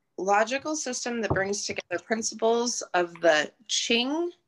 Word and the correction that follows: I Ching: I Ching. – Is it I Ching? Oh, okay. I knew I'd say that wrong.